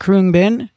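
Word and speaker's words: Khruangbin: It's K-H-R-U-A-N-G-B-I-N, pronounced Kroonbin.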